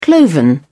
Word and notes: cloven: British English

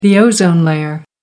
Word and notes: ozone layer: American English

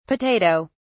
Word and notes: potato(s): British English

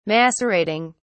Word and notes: macerating: British English

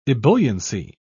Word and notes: ebulliency: British English

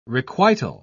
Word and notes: requital: British English

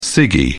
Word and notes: ciggie: British English

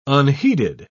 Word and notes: unheeded: British English

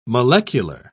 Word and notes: molecular: British English

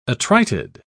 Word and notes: attrited: British English

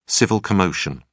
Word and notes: civil commotion: British English

